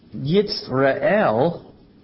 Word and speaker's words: Jezreel: Well, Jezreel, pronounced Yitzra'el, as opposed to Israel, pronounced Yisrael, comes from the Hebrew words Zara and El.